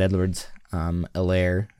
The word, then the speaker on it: Edwards-Helaire: Clyde Edwards-Helaire, the H is silent.